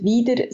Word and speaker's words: Wyder: Ähm, Lombriser ist, wird nicht als, als Lombrisier ausgesprochen, wir haben beide etwas komplizierte Namen, Lombrieser und Wieder, sind eigentlich sehr einfach zu sagen, aber sie werden immer wieder falsch gesagt.